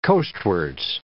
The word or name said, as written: coastwards